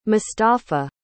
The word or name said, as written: mostafa